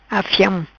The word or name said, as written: Afiam